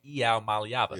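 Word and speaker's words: Iamaleava: You can make fun of me for butchering Nico Iamalivi's name. – What? That's not it either. – Hold on. Let me think. I know how to say it when I hear it. I just can't remember how to say it. That's my problem. Nico Iamalava. – Oh, my. Nico Iamalava. – Ah, that's right. Iamalava.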